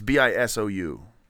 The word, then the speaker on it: Bisou: Bijou. – Bijou. I thought that's a J in Bijou. It's Bisou.